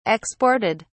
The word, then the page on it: exported: British English